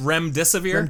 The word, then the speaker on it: Remdesivir: But Remdesivir, is that correct? – Remdesivir? – Remdesivir? Okay.